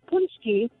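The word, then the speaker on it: pączki: It's said Poonchki, but when you say Poonchki, you're actually referring to more than one. That is the plural. An individual one is actually called a Poonchek.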